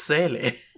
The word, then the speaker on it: Seele: It's not even pronounced seal. – It, what is it, seal? – It's Seele. – Seele? – Yes. – Well, it's a German word.